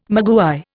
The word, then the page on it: maguey: Indian